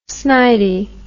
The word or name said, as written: snidey